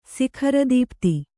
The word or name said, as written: ಸಿಖರದೀಪ್ತಿ